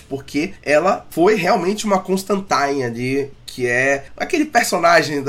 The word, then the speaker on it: Constantine: E eu começo falando, obviamente, da Johanna Constantine, que foi chamada pela pronúncia correta, de acordo com o New Game, que é Constantine.